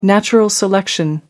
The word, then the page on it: natural selection: American English